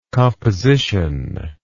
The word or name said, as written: compositively